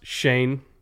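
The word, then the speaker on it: Schoen: I know it's spelled like Shone, but it's pronounced Shane.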